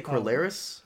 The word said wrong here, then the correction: Corlys: Oh, I say Corlys. Corlys. Thank you.